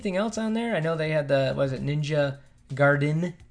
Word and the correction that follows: Gaiden: 2? – Gaiden 2, right? – Yeah. – Oh, well, I used to say that wrong all the time.